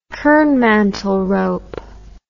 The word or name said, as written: kernmantel rope